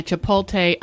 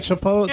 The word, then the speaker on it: chipotle: Say it right. – All right. I know, mine's the worst description. It's no, I meant the way you said chipotle. – It's chipotle, and then there's onions that go in it, but there's ketchup also with the chipotle.